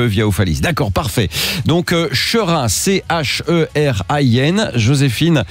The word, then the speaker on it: Cherin: On est à Cherin ce matin et ce n'est pas un jeu de mots avec serein, ça s'écrit C-H-E-R-I-N.